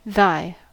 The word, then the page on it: thy: American English